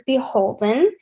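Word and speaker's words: BHLDN: The next one that we have is Beholden. That is probably not how you think it's pronounced once when you see it online, but it's B-H-L-D-N, and they are associated with Anthropologie.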